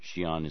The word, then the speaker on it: Xi'an: X-I-A-N is pronounced Xi'an? – Right, it's pinyin, which is the Roman alphabet transliteration of Chinese characters. And in pinyin, X-I is the same as sh or Xi.